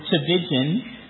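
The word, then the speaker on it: Tchividjian: Yes, his last name is Trevijan. – 他的姓是叫 Trevijan. – It sounds like a C-H, but it's spelled with a T.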